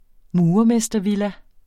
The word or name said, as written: murermestervilla